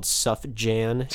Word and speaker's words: Sufjan: First name phonetically spelled Sufjan, Sufjan.